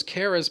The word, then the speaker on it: Kara: And by the way, guys, that's how you pronounce that character's name. It rhymes with Sarah, not Mara. The character's name is Kara.